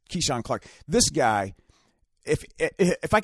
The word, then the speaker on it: Kizhan: It's spelled K-I-Z-H-A-N, but it's actually pronounced Keyshawn. It's Keyshawn Clark. – You think it would be Kazan. – Kazan, but it's not.